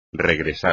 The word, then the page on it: regresar: espagnol